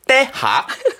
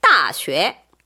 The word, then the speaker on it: 大学: 때하. – 근데 진짜 발음 너무 비슷했어. 그래서 비슷해요? 내가 약간 위협을 느꼈다니까요. – 네. – 때하기 뭐예요? 너무 완전 중국어 같잖아요. – 네. – 정답은 2번 따쉐.